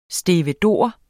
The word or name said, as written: stevedore